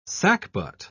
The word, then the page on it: sackbut: British English